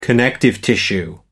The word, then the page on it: connective tissue: American English